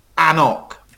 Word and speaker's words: AnCnoc: So just a couple of ones with lots of consonants is A-N-C-N-O-C. – A-N-C-N-O-C, yes. And so that's Annock.